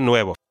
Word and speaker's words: nuevo: So the pronunciation tip I have for you all is that nuevo is a two-syllable word, right? Nue and vo.